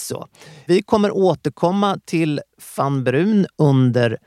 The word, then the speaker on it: Vanbruun: Fann Brun stavas V-A-N-B-R-U-U-N. Det kan vara bra att komma ihåg. – Men uttalas Fann Brun.